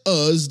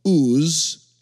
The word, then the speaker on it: Uz: In the land of Uz, they lived a man, or Uz, as the correct text would actually pronounce it.